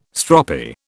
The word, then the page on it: stroppy: British English